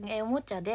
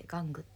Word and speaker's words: 玩具: へえ。じゃあちょっと漢語多めでキビキビ話していきます次からおもちゃで気持ち、あ違う、玩具でって読むことでキビキビする、うん、おもちゃじゃなくて玩具って読む、